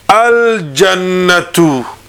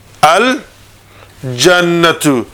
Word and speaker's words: al-jannatu: And if I put alif lam to it, al-jannatu. Al Jannatu. Now, if you listen to me, you can hear me pronounce the lam.